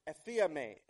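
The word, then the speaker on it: aphiame (ἀφίημι): The term forgive in our text this morning in Matthew is pronounced aphiame in the original language.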